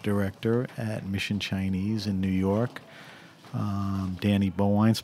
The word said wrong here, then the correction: Bowien: place. Is that the right way to pronounce Danny? – Bowen. – Bowen.